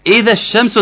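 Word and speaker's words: إذا الشمس: طب مكتوبه ازاي يا جماعه في القران؟ اذا حط الالف بعد الذال والف ولام الشمس، عندك ثلاث سواكن هيتشالوا كلهم مره واحده عشان نعرف ننطقها. ثلاث سواكن اللي هو فين؟ الالف بتاع اذا، والالف وهمز الوصل بتاع اللي هو الشمس، واللام الشمسيه، كل دول اسقطهم الثلاث حروف واقول ايه اذا الشمس، اللي هي زي ما ظهرت اول.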